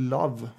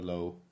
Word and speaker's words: Lowe: Eller Love, om man vill vara uttalad så, men så uttalas det väl inte. – Är det enkel eller W? – W. – Ja, då är det nog Lowe.